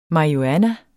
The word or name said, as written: marihuana